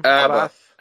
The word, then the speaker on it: Abbath: Abbath. Is that how you pronounce his name? – Abbath.